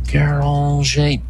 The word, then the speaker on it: Guéranger: which is a French word that is spelled G-U-R. Let me see, Dom Guéranger. G-U-E-R-A-N-G-E-R.